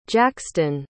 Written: jaxtyn

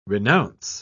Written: renounce

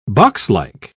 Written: boxlike

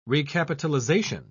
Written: recapitalization

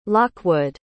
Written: lockwood

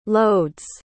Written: lodz

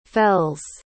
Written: fells

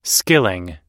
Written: skilling

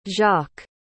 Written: jacques

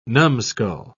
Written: numskull

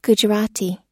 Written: gujarati

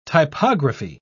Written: typography

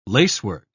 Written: lacework

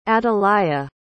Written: atalaya

